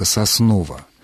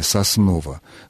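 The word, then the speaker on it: Сосново: Поэтому, когда диктор объявляет «следующая остановка Соснова», то он именно акает, потому что в первом слоге название «Соснова» звучит звук ближе напоминающий именно «а». Не совсем такой, как «а» под ударением, но он ближе к «а». Другое дело, если диктор говорит «следующая остановка Соснова», вот это уже нарушение.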